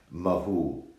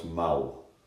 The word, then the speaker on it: Mahou: but I would pronounce it, being a mush, Mahu, but I'm told by Gilly it's pronounced Mau. M-A-H-O-U.